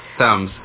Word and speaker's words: Thames: It's Thames.